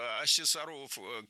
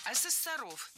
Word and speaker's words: ассессоров: Ассессоров неверно. Правильно, ассессоров. Существительное «ассессор» имеет неподвижное ударение на втором слоге. Ну а раз оно неподвижное, значит, мы его не двигаем при изменении слова. Ассессоров, ассессорами, ассессором.